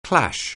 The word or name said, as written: clash